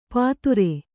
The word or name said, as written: ಪಾತುರೆ